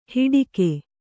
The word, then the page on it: ಹಿಡಿಕೆ: hiḍike